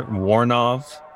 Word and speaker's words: Woronoff: I would run it together. Woronoff. – Woronoff. – I've kind of condensed that a little bit. – I like Woronoff.